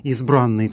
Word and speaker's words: избранный: Кстати, само название этого текста «Избранный псалом» связано именно с тем, что эти стихи выбраны, избраны из разных псалмов псалтири. Церковнославянское произношение этого термина именно такое «избранный» с ударением на «а».